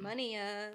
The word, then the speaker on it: munia: It's either Munia or Munia. I forgot to look at the pronunciation. – Munia. – But it's, but it's M-U-N-I-A. – M-U-N-I-A. – So of those birds we saw, yeah. M-U-N-I-A and Munia was its name.